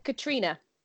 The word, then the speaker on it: Caitriona: Yeah, and then even if we go back to her, hopefully I'm saying that right, Catriona. – It's Katrina. – I was putting a lot of like emphasis on that. Okay, Katrina Balfe.